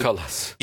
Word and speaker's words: Kallas: Hatta Kalas, bu hanımefendinin ismini çok telaffuz edememiştim. Kalas. Yani kadın Kallas. – İki tane Leyli hocam. Yani, Kallas.